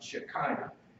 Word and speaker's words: Shekinah: And I've heard it pronounced otherwise, but I've originally taught to pronounce it Shekinah.